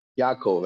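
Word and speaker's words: Yaakov (יעקב): Yaakov. That's his name. That's how you spell it. So it's a chataf patach under the ayin.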